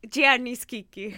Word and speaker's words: Gianni Schicchi: I want to talk about Gianni Schicchi. – Gianni Schicchi. – Gianni Schicchi. – The Italian major in me. Is Say it better. Sorry, can you say it again? – Gianni Schicchi.